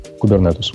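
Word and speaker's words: Kubernetes: кубернетс, как он правильно произносится Кубернетус. Кубернетус этот.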